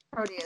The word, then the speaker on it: Proteus: But the reason I wanted to bring up, I don't know what my pronunciation I'm having, Proteus, thank you. – Proteus.